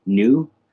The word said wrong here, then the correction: Neu: I think it's pronounced Neu, actually.